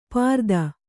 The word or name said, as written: ಪ್ರತನ